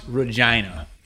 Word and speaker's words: Regina: The capital of Saskatchewan, it looks like Regina is the name of it. It's pronounced Regina.